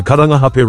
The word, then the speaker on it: Karangahape: For example, they've even figured out to say Karangahape Road properly. – In 300 metres, turn left onto Karangahape Road, signs for city centre. Turn left onto Karangahape Road, signs for city centre.